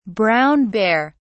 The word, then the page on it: brown bear: British English